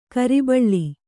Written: ಕುರಿಬಳ್ಳಿ

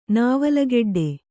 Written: ನಾವಲಗೆಡ್ಡೆ

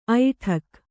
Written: ಐಡೂಕ

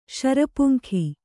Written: ಶರಪುಂಖೆ

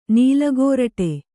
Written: ನೆಲಗೋರಟೆ

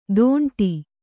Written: ತೊಂಡಿ

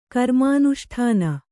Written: ಕರ್ಮಾನುಷ್ಠಾನ

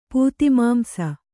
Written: ಪೂತಿಮಾಂಸ

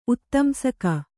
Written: ಉತ್ತಂಸಕ

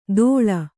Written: ದೋಳ

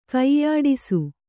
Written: ಕೈಯಾಡಿಸು

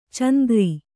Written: ಚಾಂದ್ರ

